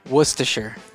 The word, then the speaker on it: Worcestershire: Worcestershire. – Worcestershire? – Worcestershire. It is not pronounced at all like it is spelled.